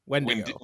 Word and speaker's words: Wendigo: But we should also mention the myth of the Wendigo. I can't pronounce this. Yes. Wendigo? – Wendigo.